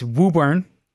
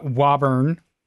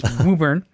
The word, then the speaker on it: Woburn: But it's anybody reading the press release, it's Woburn. It's not Wauburn. It's Woburn. Okay. You got to say it right.